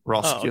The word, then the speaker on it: Roskilde: How which also haven't I probably pronounced it wrong. – Roskilde. Oh, okay. I thought Roskilde.